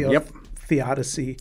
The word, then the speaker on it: theodicy: Theodicy.